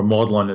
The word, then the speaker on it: Magdalene: It's the grave of a lover, and people have often suggested that one could look at Mary Magdalene, or Magdalene as it's pronounced in England, as a lover, or certainly a spiritual lover of Jesus.